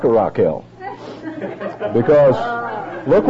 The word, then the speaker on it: Rachel: Comes out like this, R-A-C-H-E-L, pronounced Raquel. But we'll call Rachel Rachel, not Raquel.